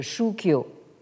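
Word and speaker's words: Shukyo (宗教): qui en réalité vient du japonais en prononciation japonaise euh, Shukyo.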